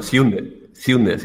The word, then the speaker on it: Sjunde: Bueno, Sjunde, si no te importa. La J se pronuncia como I. – ¿Como I? Bueno, pues entonces... Sí. Det Sjunde Inseglet.